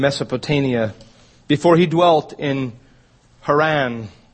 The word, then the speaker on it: Haran: Then came he out of the land of the Chaldeans and dwelt in Haran. The pronunciation is Charan.